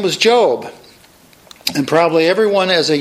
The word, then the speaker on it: Job: But we know the man's name, and we know that that's pronounced Job.